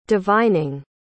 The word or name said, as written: divining